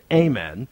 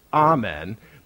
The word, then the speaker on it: amen: A word that sometimes we're not quite sure to pronounce, although the dictionary tells us that when we say it, like in a prayer, that we pronounce it amen, and that when we sing it, we pronounce it amen.